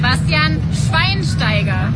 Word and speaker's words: Schweinsteiger: Bastian Schweinsteiger.